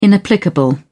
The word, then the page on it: inapplicable: British English